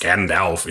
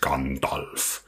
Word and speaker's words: Gandalf: And it's not Gandalf, it's Gandalf.